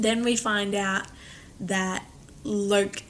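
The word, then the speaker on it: Locke: Lurk. Mm-hmm. That's how we pronounce it. You know, it's L-O-C-K-E. – Yeah. – So it's going to be Lurk for today.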